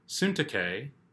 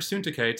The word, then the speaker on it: Syntyche: I urge Euodia, and I urge Syntyche," that is how you say it, I looked it up, "I urge Euodia and I urge Syntyche to live in harmony in the Lord.